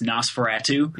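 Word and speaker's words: NOS4A2: It's a license plate and it's, you know, said phonetically, it's Nosferatu.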